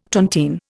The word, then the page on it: tontine: Indian